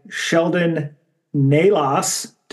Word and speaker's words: Sheldon Nalos: We are joined tonight by Sheldon Nalos.